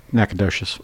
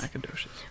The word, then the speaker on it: Nacogdoches: Nacogdoches. – Nacogdoches, Texas. – Nacogdoches.